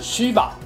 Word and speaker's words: Shiva: 湿婆这个词的意思是吉祥，在印度梵文中的发音是 Shiva，纯以音译的话，或许直接翻成西瓦，比较不会让人误以为她是女性。